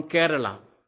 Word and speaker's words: Kerala: I am from Kerala. K-E-R-A-L-A. Recently I said this name to someone, and he pronounced it in some other way, so I felt a little bad about it.